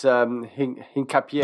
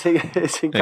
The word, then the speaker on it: Hincapié: He's Ecuadorian, but I think it's Hincapié. – Oh, shit. – it's Hincapié. Okay. It's got the accent over the E.